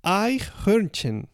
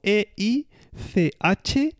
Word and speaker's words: Eichhörnchen: Se escribe como E I C H H O con diéresis R N C H E N. ¿Vale? Es decir, la primera parte es fácil, Eich, Y la segunda, Herchen.